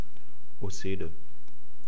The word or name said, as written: oesede